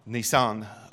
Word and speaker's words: Nisan: And so their first year, I'm going to call Abib, but is it pronounced Nisan or Nisan, the religious calendar for the first month? Nisan.